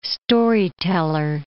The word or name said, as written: storyteller